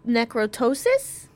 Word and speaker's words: necroptosis: N-E-C-R-O-P-T-O-S-I-S. Necrotosis? I'm assuming the P is silent? – Yeah.